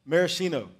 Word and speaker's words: Maraschino: How do you say the cherries? – Maraschino. – Is that right? Mm-hmm.